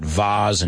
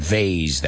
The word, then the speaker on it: vase: It's like that vase and vase that we looked at yesterday, florero, right? Vase or vase are both acceptable pronunciations for that word, V-A-S-E.